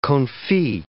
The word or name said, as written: confit